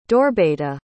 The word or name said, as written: Dorbeta